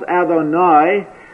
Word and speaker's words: Adonai: It is a different word which we may pronounce Adonai, and which literally means my masters.